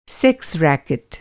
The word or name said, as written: रैकेट